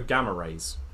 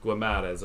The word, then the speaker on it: Guimarães: one of my favourite players to use in midfield last year on FIFA, in the man who I always purposely pronounce the name wrong, which is Bruno Guimarães. – Yeah. – Or Guimarães, I believe, is something along those lines, is how you actually say it, but I always call him Guimarães just to be unique and different.